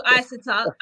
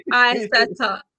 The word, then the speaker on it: Isatta: No, you can do Isatta. I'm trying to teach people how to say my name. So Isatta.